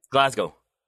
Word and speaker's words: Glasgow: Glasgow.